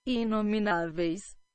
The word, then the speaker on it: Inomináveis: The, the band is Imna Ovaris. Did I even say that right? Imna Ov- sorry. Nameless is what it translates to. – Inomináveis. – Inominovai. In, so let's do it again. Inomináveis. Inomináveis.